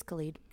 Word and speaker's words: Khalid: Khaled. Am I saying his name right? Khaled? – He's Khalid.